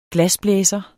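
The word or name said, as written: glasblæser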